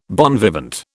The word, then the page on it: bon vivant: British English